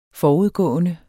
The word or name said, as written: forudgående